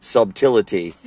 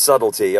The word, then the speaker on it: subtlety: I always struggle with the way that word is spelled. Subtlety. I always want to say subtlety. – Yeah. – It's subtlety, all right?